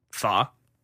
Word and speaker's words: Pho: Pho.